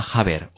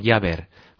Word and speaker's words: Jabber: La última red que vamos a comentar aquí es una red que se llama Jabber o Jabber, J-A-B-B-E-R. Digo Jabber o Jabber porque según quien le oigas la pronuncia de una forma o de otra.